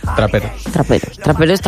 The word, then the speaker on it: traperos: Esto es los tramperos. ¿Se dice así? – Traperos.